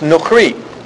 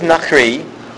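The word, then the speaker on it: nukhri (נכרי): It's a kamatz katan and it's pronounced as an O. It's nukhri. And if anyone says nachri, excuse me, they're wrong.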